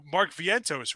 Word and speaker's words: Vientos: Now they called up this kid, Mark Vientos, right? That's how it's pronounced.